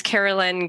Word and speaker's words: Kerelyn: It's Carolyn, K-E-R-E-L-Y-N.